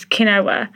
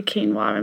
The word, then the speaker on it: quinoa: In terms of who you've grown up with, my mum always pronounced, sorry mum, but she always pronounced quinoa as quinoa, and I only a few years ago did I realise it was actually quinoa, and people laughed at me.